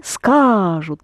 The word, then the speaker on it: скажут: Они скажут. The А is accented there.